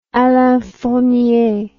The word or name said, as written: Alain Fournier